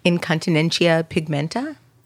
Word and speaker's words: incontinentia pigmenti: Yes, you were born with a genetic disorder called, and I, am hoping I pronounce this correctly, incontinentia pigmenti pigmented.